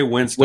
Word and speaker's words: Winstone: It's it's Ray Winston. – Winston. Right? It's pronounced Winston. I think the E is.